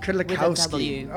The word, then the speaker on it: Kudlikowski: Kudlikowski.